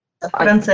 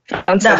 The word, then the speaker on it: Франце: Посмотрите, Константин, у меня в Инстаграме я писала о Франции, Франце, да, правильно говорить? – Франце, ага. – Франце, да.